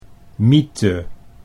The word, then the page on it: mythe: Dutch